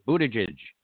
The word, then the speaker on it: Buttigieg: I saw Pete Buttigieg on – did I pronounce that correctly? Buttigieg. – Very good.